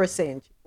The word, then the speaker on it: Assange: Is it Assange or Assange? How does he pronounce his last name? I don't know. – Assange. – Okay, so I was right the first time.